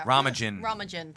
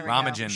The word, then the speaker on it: Romijn: Romijn. – There Romijn.